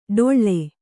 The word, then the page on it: ಡೊಳ್ಳೆ: ḍoḷḷe